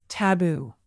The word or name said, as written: taboo